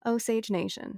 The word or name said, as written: Osage Nation